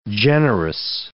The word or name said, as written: generous